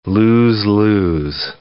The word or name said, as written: lose-lose